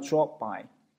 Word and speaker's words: drop by: 这个里面 drop 这个以结尾 p 结尾，后面接一个 by，p 后面加一个辅音，这个 p 呢就经常变成一个 silent p，就变成一个无声的 p，就不发音了，所以变成了 drop by。